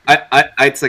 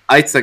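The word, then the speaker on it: Eiza: Itza Gonzalez, I think, is how you pronounce her name. – What did you say? – Itza Gonzalez.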